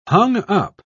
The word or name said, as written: hung-up